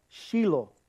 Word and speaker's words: Shiloh: So it's really Shiloh is how you say it, okay? It's Shiloh, and in my language, that's what we say.